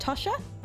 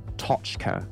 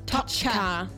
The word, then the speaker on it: Tochka: How do you say it? Tosha? – Tochka. – Tochka. I like it.